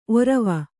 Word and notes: ಔರ್ವ: kannada

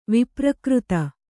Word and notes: ವಿಪ್ರಕೃತ: viprakřta

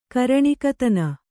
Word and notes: ಕರಣಿಕತನ: Kannada

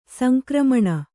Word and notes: ಸಂಕ್ರಮಣ: sankramaṇa

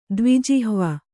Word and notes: ದ್ವಿಜಿಹ್ವ: dviijihva

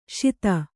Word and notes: ಷೋದಾ: Kannada

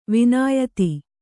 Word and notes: ವಿನಯಿತ: vinayita

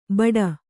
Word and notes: ಭಟ: kannada

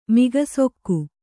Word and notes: ಮೂಕಸೆಖೆ: mūka sekhe

